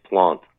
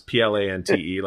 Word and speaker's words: Plante: If there's an E on the end, it's Plante. – Yeah, it is. Alex, Plante, like Jacques Plante.